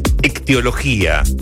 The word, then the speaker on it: ictiología: Ictiología. Con acento en la última I, por favor.